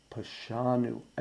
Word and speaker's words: Pashanu: I think it helps emphasize the penitential nature of this section by putting just a tiny little bit of pressure on the second syllable of those three words. Chatanu, Avinu, Pashanu.